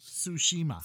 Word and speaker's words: Tsushima: Pronounced Tsushima. – Tsushima, but with the symbol crash in front, like it's Tsushima.